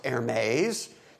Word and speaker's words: Hermes: The word hermeneutics came down to us in the English language from the god Hermes, perhaps pronounced Hermes, depending on whether you want women's bags and shoes or not.